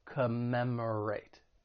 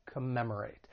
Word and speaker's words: commemorate: When pronouncing this verb, it has four syllables. The stress is on that second syllable, commemorate, commemorate. And just keep in mind that first and third syllable have that schwa sound, that unstressed vowel sound, which sounds like a, commemorate, commemorate.